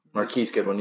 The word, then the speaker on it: Marquise Goodwin: Oh, Marcus Goodwin. – Marquise Goodwin. You can't even say his name right.